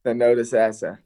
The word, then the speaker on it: Thonotosassa: Thonotosassa.